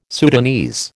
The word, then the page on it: Sudanese: American English